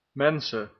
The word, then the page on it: mensen: /ˈmɛn.sə(n)/; /men.sə:/